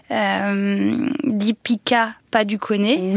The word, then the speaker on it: Deepika Padukone: Deepika Padukone.